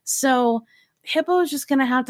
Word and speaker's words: Hippeaux: Hippo is spelled H-I-P-P-E-A-U-X.